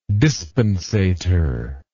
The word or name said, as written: dispensator